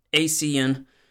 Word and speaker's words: ASEAN: And these include the Association for Southeast Asian Nations, which I believe is pronounced ASEAN, includes India, Australia, Japan, South Korea, the Philippines.